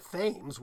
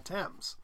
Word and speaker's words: Thames: It's Thames, and Inkhorn people put in the TH to make it look Greek, and then some of these people came over to the States, and they ended up doing the spelling pronunciation, calling it Thames, whereas in England it's still called Thames.